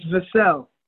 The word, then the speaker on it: Vassell: It's Vassell. – It's Vassell? – Yeah, all right.